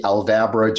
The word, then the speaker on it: Aldabra: It's the Aldabra giant tortoise.